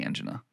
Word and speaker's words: angina: So you, in recent years, have been saying angina, all right? – And I don't always, like saying angina. – Is it just because you don't like saying it? Because it can be pronounced either way, correct? – I think it can be pronounced either way.